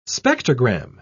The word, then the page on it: spectrogram: British English